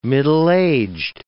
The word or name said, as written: middle-aged